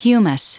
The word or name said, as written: humus